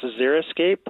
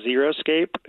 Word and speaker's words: xeriscape: And in fact, people always mispronounce xeriscape, which is spelled with an X as xeriscape. It's not. It's xeriscape is when you just, you know, fill your park trip with rocks. Xeriscape is when you fill it with low water or no water plants.